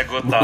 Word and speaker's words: Gothard: Me corrija, inclusive, sua pronúncia. O seu nome é Gotá mesmo, seu sobrenome é esse. É Gotá.